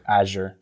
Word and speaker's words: Azure: He mentioned building the back end on Azure. Am I saying that correctly? – Azure.